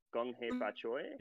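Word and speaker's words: Gong Hei Fat Choi: the correct way to say it again Gong Hei Fa mm-hmm. Choi? – Yep. Or am I mistaken? – Oh, so I'm not mistaken.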